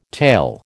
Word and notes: tale: American English

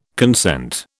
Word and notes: consent: British English

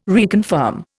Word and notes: reconfirm: Indian

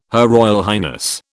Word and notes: HRH: British English

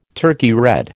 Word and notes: Turkey red: American English

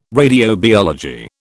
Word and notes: radiobiology: British English

